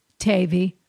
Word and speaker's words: Tavi: With love and thanks, Tavi, rhymes with gravy.